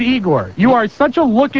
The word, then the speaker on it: Igor: And incidentally, why don't you pronounce it the correct way? Igor.